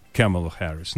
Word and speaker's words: Камала Харрис: Камала Харрис. Но хорошо, ладно, будем поставить правильное ударение.